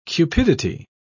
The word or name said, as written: cupidity